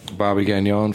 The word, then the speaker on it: Bobby Gagnon: Bobby Gagnon from The Gate in Park Slope, Brooklyn.